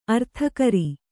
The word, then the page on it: ಅರ್ಥಕರಿ: arthakari